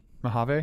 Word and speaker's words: Mojave: Mojave.